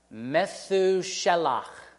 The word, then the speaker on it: Methuselah: Enoch named his son Methuselah. That would be a more precise Hebrew pronunciation.